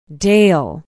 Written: dale

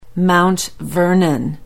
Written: Mount Vernon

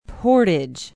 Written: portage

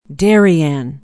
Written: Darien

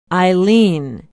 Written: eileen

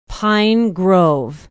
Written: pine grove

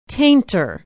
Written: tainter

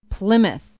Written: plymouth

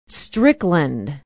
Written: strickland

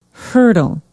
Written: hertel

